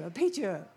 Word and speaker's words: arpeggio: Arpeggio.